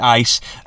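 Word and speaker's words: ice (εἰς): Some people would try to argue that the Greek word ice, you pronounce it like that, ice, actually means because you have already obtained.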